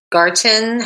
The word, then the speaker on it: Ina Garten: And the one that I found to be like the most exciting was the um, Ina Garden. Garten. How do you mm-hmm. say her name? Ina Garten.